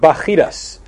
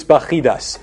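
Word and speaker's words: Bacchides: He confirms that Alcimus should be the high priest, and he sends an army under the supervision of Bacchidas. That's the Hebrew pronunciation. The English is spelled B-A-C-C-H-I-D-E-S. I'm not even sure how the Greek pronunciation goes, but in the Hebrew, it's Bacchidas.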